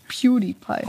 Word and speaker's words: PewDiePie: Ich hoffe, den habe ich so richtig PewDiePie. – ausgesprochen. – Ja, heute äh, Platz 2 ist aber der Mr. Beast-Kanal.